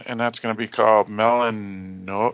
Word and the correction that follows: Metanoia: No. You're butchering this. I looked at it earlier. Hang on. Metanoia. – Metanoia. Oh, yeah, that's a T, isn't it?